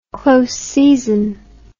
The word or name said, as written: close season